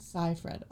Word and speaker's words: Seyfried: I think Amanda Seinfeld? Is that how you say it? – Seyfried. – I knew it was like S. Mm-hmm. It's like S-E-I, and it ends with a D.